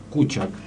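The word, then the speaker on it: kučak: Otros historiadores consideran posible también una conexión con el término serbo-croata kukak, que se pronuncia kuchak, perdón, y el persa kusiak, que también significan perros.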